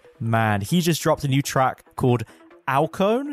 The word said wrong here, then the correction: Alcyone: Is that how you say it? – I think it's Alcyone, dude. – Alcyone? Oh, Jesus, it probably is. Alcyone. Come on. There we go.